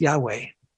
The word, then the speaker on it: Yahweh: And that's where the second half comes in, the Yah, J-A-H. And it's J-A-H is a shortened form of the Hebrew tetragrammaton, which is the four letters Y-H-W-H, which is usually pronounced Yahweh.